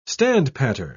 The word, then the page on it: standpatter: British English